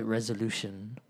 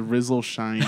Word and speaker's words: RZLSHN: It was R Z L S H N. – Okay. Oh, is that what I was? Mm-hmm. I was completely mispronouncing that. I thought it was a Rizzle Shine or something like that.